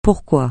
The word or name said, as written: pourquoi